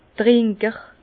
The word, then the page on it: Trinker: Bas-Rhin; Herrlisheim